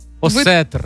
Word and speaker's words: осетр: А козероги у нас осетер. – Осетр. – Ви... Осетр. В мене прям з рибою не склалося.